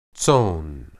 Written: tson

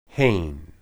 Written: hen

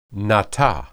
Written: natah

